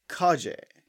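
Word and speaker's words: Khajeh: I'm Asan Kaje.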